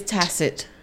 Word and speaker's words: tacit: Tack it. Whoa! – It's tacit. – Tacit? Is that tacit? – Is that how you say it? – Tacit. – T-A-C-I-T.